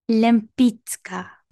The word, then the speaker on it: Lempicka: This show is based on a real life person, uh, Tamara de Lempicka, or as I found out beforehand with the Polish pronunciation, it would be, what is it, Lempiska? Is that what we decided? – Lempicka.